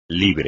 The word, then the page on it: libre: Spanish